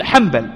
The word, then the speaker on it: حنبل: تنطق بشكل، أحمد ابن حنبل، لكن كيف تكتب؟ حاء نون باء ثم ماذا؟ ثم اللام. ولكن من ناحية النطق ماذا؟ نقول حنبل معي بارك الله فيكم.